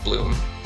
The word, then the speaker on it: 8LOOM: Of course, yeah, listeners, only listeners won't get that, but yeah, it is spelled 8, L-O-O-M, but pronounced like Bloom.